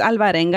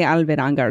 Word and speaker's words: Alvarenga: It's Alvarenga, and I always say Alveranga or some shit like that.